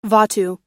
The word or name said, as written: vatu